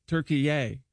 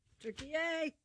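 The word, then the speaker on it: Türkiye: So he immediately demanded that it be pronounced Turkey-ye. – Turkey-ye. – T-U-R-K-I-Y-E.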